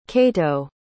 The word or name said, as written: kato